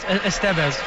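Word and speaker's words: Estévez: Right, and the reason why you do a double switch is, it's actually pronounced Estevez.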